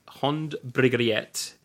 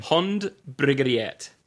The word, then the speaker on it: Hond Brygget: This is the Hond Brygget. That's how Kjetil from Nogna told me how to pronounce it, guys.